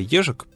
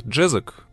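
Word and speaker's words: Ježek: И так уж получается, что фамилия Ежик при прочтении на английский манер преобразуется в Джезок. Но поскольку уже устоялось произношение фамилии нашего сегодняшнего героя как Джезок, я буду придерживаться ее, хотя это и не совсем верно.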